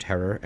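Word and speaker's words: Herer: Pronounced Herrer and rhymes with terror, as he always lovingly barked at anyone who mispronounced his name.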